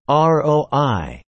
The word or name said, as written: ROI